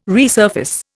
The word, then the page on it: resurface: Indian